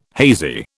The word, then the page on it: hazy: British English